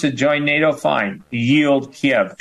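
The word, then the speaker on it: Kyiv: Kiev is the Russian pronunciation. And to show people, kind of like virtue signal, they're going with a Ukrainian Kiev as opposed to Kiev.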